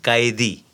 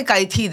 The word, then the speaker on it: Kaithi: Which is called Kaithi, actually pronounced Kaidi. – Yeah, so you don't have to say Kaithi then, you have to say Kaidi.